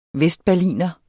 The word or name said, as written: vestberliner